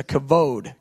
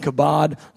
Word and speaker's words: kavod: So it's it's the light B, which is really a V in Hebrew. It's a kavod, kavod. And I was saying kabod last week. They said, no, brother, it's kavod. And I said, I think it might have the sound at the beginning. They said, no, brother, it's a K sound at the beginning. So it's kavod. Kavod. Kevin Dangler, God bless you. Thank you for helping me straighten me out.